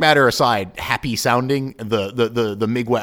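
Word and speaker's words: Mgła: It's Mgla, but the L's got some kind of weird accent, which I believe in Polish is pronounced like a W.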